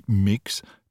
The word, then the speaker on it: Mx: le terme MX, prononcé mix, pour désigner Martine, qui s'identifie comme ni strictement femme ni strictement homme.